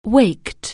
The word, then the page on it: wake: anglais